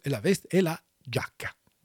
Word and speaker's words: giacca: Pour prononcer giacca, Je l'ai sous les yeux, moi. – G-I-A pour faire gia. – Ouais. – G-I-A. Le I, on ne le prononce pas, mais il a pour faire je.